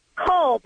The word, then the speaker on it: Cole: Cole. Paula Cole. – Cole. – Yes. – Rhymes with grohl. – Cole rhymes with grohl. – That's correct.